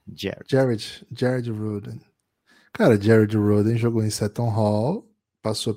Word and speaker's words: Rhoden: Jared Roden entrou em quadra. Caramba. Roden se escreve R-H-O-D-E-N.